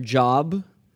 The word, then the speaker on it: Job: So, the book of Job, spelled like the word job, but it's pronounced Job.